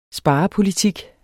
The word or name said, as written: SPAREN